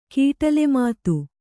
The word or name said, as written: ಕೀಟಲೆಮಾತು